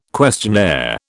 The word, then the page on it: questionnaire: British English